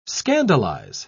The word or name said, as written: scandalize